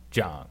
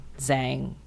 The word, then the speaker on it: Zhang: I just spell it Z-H-A-N-G. – Oh, but it still would be Zhang. – Yeah, well, I... Zhang, like Zhang. Whatever.